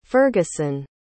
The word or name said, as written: ferguson